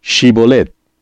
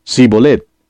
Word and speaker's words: Shibolet: Si él respondía, «No», entonces le decían, «Ahora pues, di Shibolet». Y él decía, «Sibolet», porque no podía pronunciarlo correctamente. Entonces le echaban mano y le degollaban junto a los vados del Jordán.